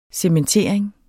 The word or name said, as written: cementering